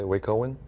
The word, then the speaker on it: Wacoan: How do you say Wacoan? – Wacoan.